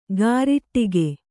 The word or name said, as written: ಗಾರಿಟ್ಟಿಗೆ